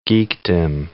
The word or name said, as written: geekdom